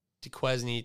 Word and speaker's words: Duquesne: It's that Duquesne thing.